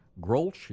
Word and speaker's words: Grolsch: and Grolsch. Is that how that's pronounced? – That is correct.